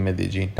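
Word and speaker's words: Medellín: خب شهر مدجین یا حالا بعضیا که میگن مدلین بعضیا میگن مدئین، این شهر خب بالاخره از نظر حالا اسمشو بخوام بگم که توی اسپانیایی خب یه یا ج یا ژ بسته به کشورش تلفظ میشه، ولی خب دیگه حالا تو کلمبیا میگن مدجین، بین ج و ی ما هم دیگه میگیم مدجین.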